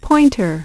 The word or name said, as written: pointer